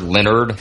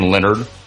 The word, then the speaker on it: Leonhard: You know who's not on my ass list, and it goes to the AFC East, is Leonhard Leonard. How do you say his name? He's one of my favorite coaches. Jim Leonard. – Dude, stop. – He's spelled like Leonhard.